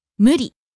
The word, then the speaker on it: 無理: Next, 無理. – Unreasonable, impossible. – 無理. 無理. – Falling on the second syllable, di.